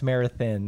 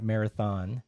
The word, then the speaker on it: Marathon: Pronounced Marathon, not Marathon.